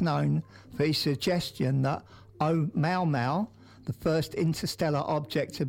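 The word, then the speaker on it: Oumuamua (ʻOumuamua): So I spoke to our linguistic department when I first got here, and apparently that is a pronunciation is Oumuamua, which is a Hawaiian word which means scout and reach.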